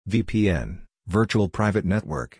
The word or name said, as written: VPN (Virtual Private Network)